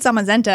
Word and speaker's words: Zamazenta: When I heard Zamazenta, I was like, I'm going to remember how to pronounce it because it sounds like magenta.